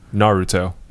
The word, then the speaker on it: Naruto: Naruto.